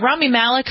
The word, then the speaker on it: Rami Malek: Lead actor in a drama went to Rami, is it Rami Malek from Mr. Robot? – Yeah.